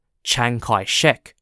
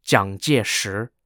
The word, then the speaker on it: Jiang Jieshi: Jiang Jieshi is often known by his romanticized name, Chiang Kai-shek. However, in this three-part series, I'll be referring to him as Jiang Jieshi, the Mandarin Chinese pronunciation of his name.